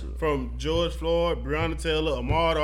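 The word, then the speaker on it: Ahmaud: Ahmad Arbery.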